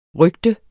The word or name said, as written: RYGTERNES